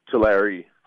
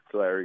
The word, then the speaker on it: Tulare: Did you grow up in the town of, correct me if I'm pronouncing this right, Tulare, California? – Tulare. Tulare, yeah. – Tulare. Excuse my pronunciation.